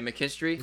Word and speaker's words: McKinstry: In Zach McKinstry, is that saying that right? Yeah, McKinstry, mm-hmm.